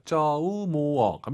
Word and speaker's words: 周末: 쪼, 모 아니고, 오유는 어우예요. 쪼우, 모어, 갑니다.